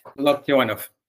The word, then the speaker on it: Loktionov: Loktionov.